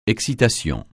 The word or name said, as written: excitation